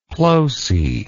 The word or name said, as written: ploce